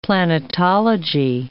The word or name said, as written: planetology